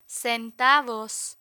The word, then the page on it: centavos: spanish